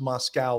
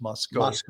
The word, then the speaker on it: Moscow: And that's why I think that the it's apparently it's not pronounced Moscow like in the Soviet Union. It's pronounced Moscow.